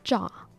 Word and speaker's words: cho: And so your Viet lesson today will be the word for give, which is cha. Cha, C-H-O, is how you would spell it. But the C-H isn't a hard C-H, it's a softer, more muted C-H.